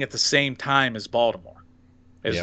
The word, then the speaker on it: Baltimore: You're having too many syllables in there. It's not Baltimore. – Yes, Baltimore.